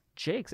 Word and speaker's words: Jacques: The Jacques Award winner, Jacques, right? Did I say that correct? – Jakes. – It's spelled like Jacques, though. – Yeah. – It's Jakes, though.